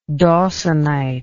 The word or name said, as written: dawsonite